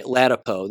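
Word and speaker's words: Ladapo: There was also, of course, Dr. Lapido, if I'm saying his name correctly, the Surgeon General. I'm sorry, Latipo. There we go.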